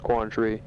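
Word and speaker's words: Quang Tri: Now Way is spelled H-U-E, and it is pronounced Way. Onkay is pronounced Onkay, not Onkay. So, Quantry, I don't know how it's spelled, Q-U-A-N. Capital T-R-I, it's pronounced Quantry.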